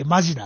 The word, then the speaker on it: 本気: まま本気と書いてマジなんですよ。